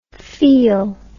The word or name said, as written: feal